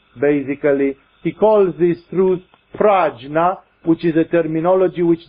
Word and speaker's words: prajna: prajna paramita, pragya, if you want to put it in a more actual pronunciation in Sanskrit.